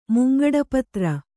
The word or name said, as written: ಮುಂಗಡಪತ್ರ